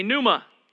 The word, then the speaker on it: pneuma: All right, but the P is silent, so it's just pneuma.